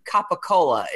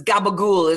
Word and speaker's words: capicola: And you would see it if you were pronounced, it would be Capicola is what. But it's gabagool is how they say it.